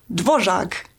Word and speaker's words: Dvořák: So the best example, for example, is dvořák so the accent is on the first syllable, but still you have the long um, second note, and this is where all these syncopated rhythms come from.